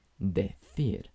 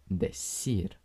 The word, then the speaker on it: decir: En muchas regiones de España se pronuncia con el sonido th, decir, mientras que en la mayoría de los otros países se pronuncia con el sonido s, o sea, decir.